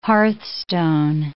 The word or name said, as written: hearthstone